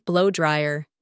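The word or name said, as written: blow dryer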